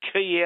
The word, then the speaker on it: quay: How do you get key out of Q-U-A-Y? – You know what?